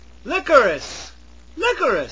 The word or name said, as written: licorice